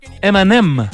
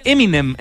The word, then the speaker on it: Eminem: Y los gallos lo pronuncian M&M. Claro. Como los dulces, sí. Los M&M's. Entonces, claro. M&M es un juego como de palabras, M&M. Y nosotros decimos Eminem, entonces no entienden.